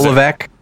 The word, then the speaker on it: Levesque: Paul Lavis. How do you say his last name? Paul Levesque. Yep, Steve Corin, who is chief executive officer of the Atlanta Hawks and State Farm Arena.